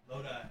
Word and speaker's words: Lodi: Pronounce, is it Lodi or Lodi? Or Lodi. – Lodi, cool.